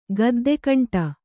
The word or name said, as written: ಗದ್ದೆಕಂಟ